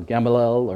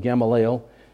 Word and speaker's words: Gamaliel: And this man, actually, whom Paul sat at the feet of, learning, Gamaliel, I've heard people pronounce his name different ways, you know, or Gamaliel or whatever, but I always pronounce it Gamaliel.